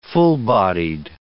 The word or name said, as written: full-bodied